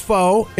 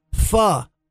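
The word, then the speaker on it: phở: but it's not pronounced Pho. If you're familiar with Vietnamese food, it's Pho.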